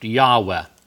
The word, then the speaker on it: Yahweh: The name Jehovah, probably pronounced Yahweh in Hebrew, comes from this phrase, I am, or the He is.